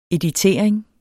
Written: editering

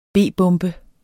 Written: b-bombe

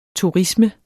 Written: turisme

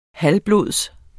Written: halvblods

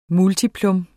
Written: multiplum

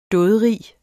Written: dådrig

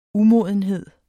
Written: umodenhed

